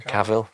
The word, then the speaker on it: Cavill: And I don't know how much training um, Henry is it Carville? Is that how you pronounce him? Cavill.